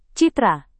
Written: citra

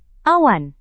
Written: awan

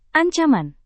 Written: ancaman

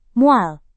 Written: mual

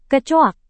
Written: kecoak

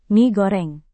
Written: mie goreng

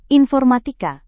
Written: informatika

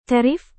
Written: tarif

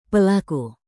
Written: pelaku

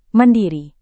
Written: mandiri

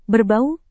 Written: berbau

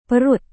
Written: perut

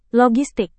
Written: logistik

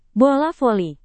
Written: bola voli

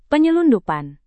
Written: penyelundupan

